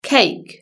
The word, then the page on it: cake: British English